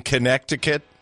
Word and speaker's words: Connecticut: Yeah, it doesn't make any sense the way we pronounce it, Connecticut. We drop the C before the T.